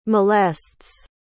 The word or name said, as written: molests